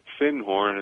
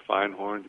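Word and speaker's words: Findhorn: And she had studied at Finhorn in Scotland, or Finehorn, depending on how you like to pronounce it.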